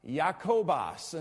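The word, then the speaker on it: Jakobos (Ἰάκωβος): Yeah, as a matter of fact, you might notice here that it calls him James, but it is pronounced Jakobos, and so it is a form of the name Jacob.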